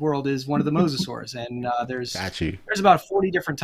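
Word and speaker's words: mosasaur: Was it mosasaurs? Did I pronounce that Mosasaurs. – Right? – Mosasaurs. Think of your favorite biblical patriarch. They are the mosasaurs, and they're marine reptiles.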